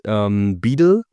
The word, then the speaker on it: BUIDL: Dann, BlackRock hat ja seinen Beedle Treasury Fund, ich kann es nicht richtig aussprechen, es wird BUIDL geschrieben, so wie Bild, nur halt Beedle.